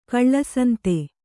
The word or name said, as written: ಕಳ್ಳಸಂತೆ